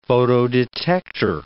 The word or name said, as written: photodetector